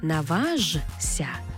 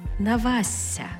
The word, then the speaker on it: наважся: Тобто не «наважся», а «навася».